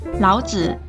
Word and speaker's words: 老子: 老子有两个读音，三声时是人名，轻声时是口语，爸爸或者我的意思。